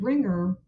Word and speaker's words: wringer: The expression to be put through the ringer or to put someone through the ringer, we use the word ringer spelled with a silent W at the beginning. So it's spelled W-R-I-N-G-E-R. And for some native speakers, for people learning the language, they may write that expression using ringer just with an R because they sound the same. They are homophones.